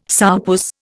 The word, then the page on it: sourpuss: Indian